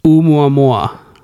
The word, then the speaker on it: Oumuamua: Oumuamua. – Yeah, that's it. – Did I say it right? I don't know. – Yeah, I think so.